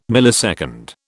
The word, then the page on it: millisecond: British English